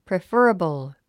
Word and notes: preferable: préfərəbl; プレファラボォ

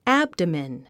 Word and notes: abdomen: ǽbdəmən; アブドメン

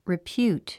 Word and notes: repute: ripjúːt; リピュート

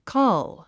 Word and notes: cull: kʌ́l; カァゥ

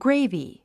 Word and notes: gravy: ɡréivi; グレイビィ

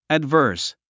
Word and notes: adverse: ædvə́ː r s; アッバース

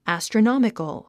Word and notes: astronomical: æ`strənάmikəl; アストロノミカル

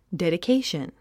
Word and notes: dedication: dèdikéiʃən; デディケイション

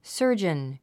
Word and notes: surgeon: sə́ː r dʒən; サージャン

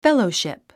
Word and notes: fellowship: félouʃìp; フェロォシップ

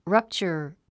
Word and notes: rupture: rʌ́ptʃər; ラプチャァ

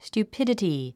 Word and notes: stupidity: st j uːpídəti; スチュゥピディティ

